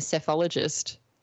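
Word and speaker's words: psephologist: I did do a quick search myself, but I'd love to hear from you what is involved in being a sophologist. – It just involves the studying of elections, voting, voter behaviour, and all those kinds of things. It's a funny sounding word with a silent P at the front of it that I believe comes from something involving pebbles.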